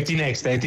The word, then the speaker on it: Tinext: Perché ero indecisa se pronunciarlo all'inglese Tinext oppure Tinext. – No, è Tinext.